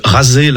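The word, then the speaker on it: Razé (غزة): Razé, la prononciation arabe de Gaza.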